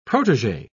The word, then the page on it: protegee: British English